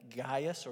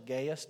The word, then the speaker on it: Gaius: 3rd John is written to a guy named Gaius, or Gaius, depending on how you want to pronounce it.